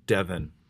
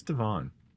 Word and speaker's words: Devon: And she pronounces the street name Devon as Devon. It's Devon. But it's okay.